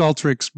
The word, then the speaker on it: Alteryx: I'm saying it wrong. It's Alteryx because, although it's spelled Alteryx, because it's Alteryx, yes? – Alteryx and Alteryx and X, yes, exactly. It's pronounced Alteryx, but you can call us whatever you want.